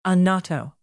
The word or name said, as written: annato